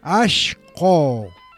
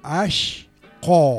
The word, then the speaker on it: ashcko: Ashko. Atento a la palabra en quichua, ¿eh? A-S-H-C-K-O. Ashko. – Ashko.